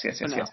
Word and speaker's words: Fennell: Emerald Fennell, right? – Fennell, yeah. – Fennell, yes. Emerald Fennell for Promising Young Woman.